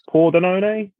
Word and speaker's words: Pordenone: and I am taking a home win for Brescia against, the pronunciation is going to be horrible, Pordenone? – Yeah, that's right.